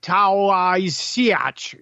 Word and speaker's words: Taoiseach: The report says T-A-O-I-S-E-A-C-H. Looks like Taoiseach. It's Taoiseach. That's how it's pronounced.